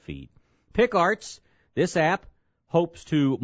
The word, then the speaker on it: Pheed: Then there's one called Feed, which you may not have heard of. It's spelled P-H-E-E-D.